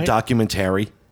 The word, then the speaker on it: documentary: You're putting the emphasis on the wrong syllable. – Documentary. – No, documentary. – Documentary. – Documentary. You're putting he's putting the emphasis on the wrong syllable. – I don't think that's it. I'm incorrect. It's a documentary.